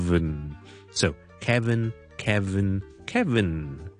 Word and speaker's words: Kevin: Kevin, K-E-V-I-N, 第一件事没有 L 的，我们没有 Keldo, not Kelvin, it's K-K-K-E-E-E 就可以了。 Kevin, Kevin, Kevin。